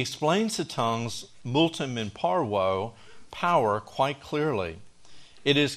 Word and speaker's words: parvo: In short, James, the half-brother of Jesus, explains the tongues multum in parvo. Now, the way you pronounce that is like M-U-U-L-T-U-M, multum in par, and the V is a W in parvo.